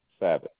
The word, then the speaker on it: Savage: It is Jerome Savage. – Can you spell that last name for me? – S-A-V-A-G-E.